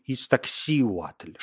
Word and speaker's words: Истаксиуатль: Вот здесь желательно твердое ударение на «и». Истаксиуатль, что переводится с языка натль как «белая женщина».